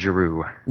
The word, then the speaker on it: Giroux: Now, how do you pronounce FSG? – I say Farrar Straus and Giroux. – Giroux, okay.